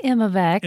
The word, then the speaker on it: Imivax: I hope I pronounce this right. Imivax. – Actually Imivax. – Imivax. – But I know it's so confusing. Our brains like have trained us to say I with everything because of Apple, I think.